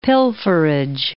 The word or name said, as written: pilferage